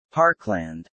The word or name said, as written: parkland